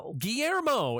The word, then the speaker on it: Guillermo: Why is there an extra syllable? Guillermo. – He there, you said it perfectly.